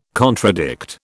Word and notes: contradict: British English

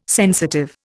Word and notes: sensitive: Indian

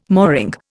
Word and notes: mooring: Indian

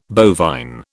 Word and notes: bovine: British English

